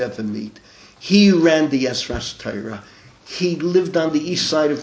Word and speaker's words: Ezras Torah: Ezras Torah, I'm pronouncing it the way they do, not Ezrat Torah.